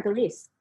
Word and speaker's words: Gillease: You absolutely are, Gillease.